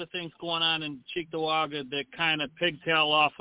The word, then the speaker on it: Cheektowaga: You've got to pronounce it correctly. It's Cheektowaga. – Cheektowaga? – Cheektowaga.